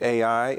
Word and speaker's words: Ai: So Joshua sent men from Jericho to Ai, and I think the proper way to say that word in Hebrew is I, but I learned it Ai,